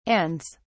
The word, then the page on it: ands: British English